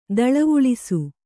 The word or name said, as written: ದಳವುಳಿಸು